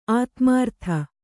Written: ಆದಿಮೂರುತಿ

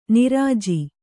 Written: ನಿರುಜ

